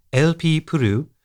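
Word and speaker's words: Elpeo Ple: Although pronounced LP Puru, her name is usually written LPO Ple.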